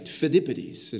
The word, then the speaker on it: Pheidippides: I actually texted Erikos earlier this week to make sure I had the pronunciation right. Pheidippides is how you say it.